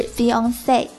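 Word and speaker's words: fiancée: Fiancé F-I-A-N-C-E 表示未婚夫，未婚妻的发音与未婚夫的发音完全相同，都是 Fiancé，但是呢，未婚妻的英文比未婚夫的英文结尾多了个字母 E。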